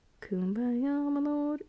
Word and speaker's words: kumbaya: kumbaya. Is that how you say it? Kumbaya? Yeah. Kumbaya, my lord. Yeah, I got it right.